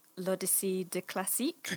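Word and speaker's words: L'Odyssée de Klassik: Yeah, actually, that's a really nice segue into talking about L'Odyssee de Klassik. – Yes. – I hope I said that right. – Yes.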